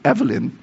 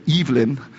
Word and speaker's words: Evelyn: I think in America we pronounce it Evelyn. But there, they call it Evelyn.